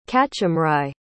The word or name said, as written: cachamwri